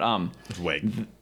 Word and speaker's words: Wiig: like Will Ferrell and Kristen Wiig. I don't know how you say it, but Wiig. – It's just Wiig. Yeah.